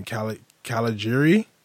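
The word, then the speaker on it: Cagliari: I can never pronounce this team's name. Calgary. – Yeah. – That's my Italian. – It's a silent G.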